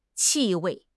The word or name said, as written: 气味